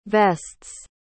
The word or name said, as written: vests